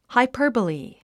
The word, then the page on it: hyperbole: haipə’ː r bəli; ハイパーボリィ